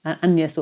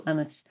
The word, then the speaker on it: Agnes: And he refers to her as my daughter, Agnes, or it would have been pronounced close to the French, Agnès or Annis.